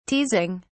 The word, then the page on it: teasing: British English